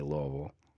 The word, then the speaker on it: Louisville: I mean, I'm from Kentucky, so we always get torn up about how people say Louisville. – Oh, yeah. – And okay. – You got to speed that one up, too. – Yeah. If you pronounce that S, you're in trouble.